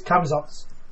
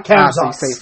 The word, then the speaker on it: Camazotz: It's Camazotz. Camazotz. – Camazotz. – Not Camazotz. – Say it fast, basically.